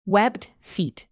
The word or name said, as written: webbed feet